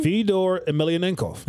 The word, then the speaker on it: Fedor Emelianenko: Say that name again? – Fedor Emelianenko. – That's a great pronunciation of that name.